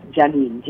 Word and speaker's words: Janine: And the number nine, the word nine, the English spelling of the number nine, N-I-N-E, is attached to my name. My name is spelled Janine, J-A-N-I-N-E.